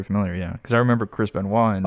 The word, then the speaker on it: Benoit: Like obviously like the WWE has a big, don't mention that anybody that knows Chris, Chris Benoit.